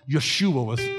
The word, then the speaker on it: Yeshua: Yeshua is how you say it in Hebrew.